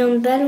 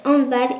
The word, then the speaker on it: handball: Les premières règles du handball ont été inventées en 1919 en Allemagne. C'est pour cela que l'on prononce handball et non pas handball, car ce n'est pas un sport inventé en Angleterre.